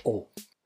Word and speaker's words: eau: Il problema è che a livello fonetico la combinazione EAU si pronuncia O. Quindi abbiamo tre vocali, EAU, e il suono della combinazione di questi tre vocali è una quarta vocale, la O.